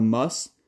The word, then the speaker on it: MUSS: And losing the student section known as the MUSS, again, that's pronounced the MUSS, that could really hurt, guys.